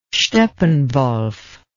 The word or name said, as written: steppenwolf